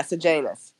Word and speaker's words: Sejanus: However you say his name. – Sejanus? – Yeah, Sejanus. – Okay, that's what I'm hearing a lot of people say.